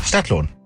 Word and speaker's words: Stadtlohn: Mit Betonung auf Stadt, Stadtlohn.